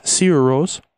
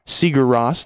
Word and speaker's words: Sigur Rós: He's back, and uh, I guess that's uh, that's Sigur Rós or Seeger Ross, depending on who you ask. I am under the impression it is the first way with which I pronounced it.